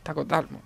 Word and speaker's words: Tlacotalpan: Tlacotalpan. – Tlacotalpan, vamos.